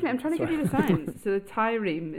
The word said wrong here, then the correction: tyramine: is that how you say it? Tyramine? – Tyramine, causes the adrenal gland.